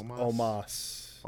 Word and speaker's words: Omos: One day you will say his name correctly. – Omos? – It's Omos.